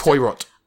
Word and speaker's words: Poirot: It's pronounced Poirot. – I watched a lot of Pokemon. – Poirokimon. – No, I don't think I can. – I would watch a version of Pokemon that was focusing on Poirot.